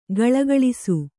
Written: ಘುಳುಘುಳಿಸು

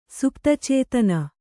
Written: ಸುಪ್ತಚೇತನ

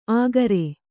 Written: ಆಗರೆ

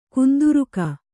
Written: ಕುಂದುರುಕ

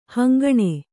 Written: ಹಿಂಗಣ

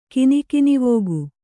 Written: ಕಿನಿಕಿನಿವೋಗು

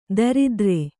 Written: ದರಿದ್ರೆ

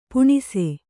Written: ಪುಣ್ಸೆ

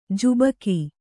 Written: ಝಾಬುಕ

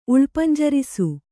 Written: ಉಳ್ಪಂಜರಿಸು

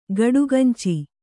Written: ಗಡಗಂಚಿ

